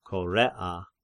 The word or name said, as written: Korea